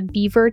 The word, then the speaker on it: Biewer: Just a few weeks ago, the American Kennel Club added the Beaver Terrier to the list. – Beaver? – Yeah, it's spelled B-I-E-W-E-R.